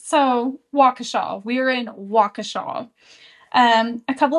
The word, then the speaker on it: Waukesha: So, when I said the name of the town last episode, I think I said, like, Waukesha or something like that. That is not the name of the town. It's Waukesha.